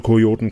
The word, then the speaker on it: Kojoten: Kioten, ich habe das falsch ausgesprochen. Also Kojoten quasi.